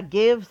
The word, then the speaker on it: GXVE: But on my lips right now, I am wearing, I think, one of my key favorite things this year are the Give. Is that how you say it? Give? – It's GXVE, but yeah, Give sounds right to me.